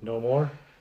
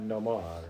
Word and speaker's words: Namor: That's not how they said it. – Oh, they don't say it like that? We've been saying it wrong all these years? – No, I think they said it wrong. – Okay. What is it? No more? – Yeah, something like that.